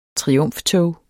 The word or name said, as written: triumftog